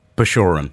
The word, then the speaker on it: Bashorun: Go on, you do the pronunciation. Joe Bacheron. – Bacheron. Yeah, great to see you, Joe.